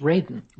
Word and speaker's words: Radin: And my last name is pronounced Radin.